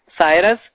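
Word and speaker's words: Cyrus: انگریزی میں اسی نام کا تلفظ سائرس سائرس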